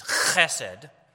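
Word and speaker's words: chesed (חסד): The Hebrew word for this is chesed. If you're taking notes, you can spell it H-E-S-E-D. If you want, you can put a little dot under the H, and that signifies that that H makes the ch sound of the Hebrew letter chet.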